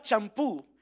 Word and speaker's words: champú: la palabra champú en inglés es S-H-A-M-P-O-O. o o y en español, ya es una palabra legítima del español, es C, C de coco, H, A, M, P, U, U con acento en la U, porque es una palabra aguda y las palabras agudas se acentúan cuando terminan en N, S o vocal, y por lo tanto champú se acentúa, aunque viene de la palabra en inglés champú, ¿verdad que sí?